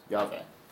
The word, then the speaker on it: Yahweh: Jehovah has been rendered in the English Bible L-O-R-D, all capitals. And it comes from the Hebrew tetragrammaton, YHWH, or Yahweh, which is pronounced as Yahweh. Note there are no J's in Hebrew. So it's Yah.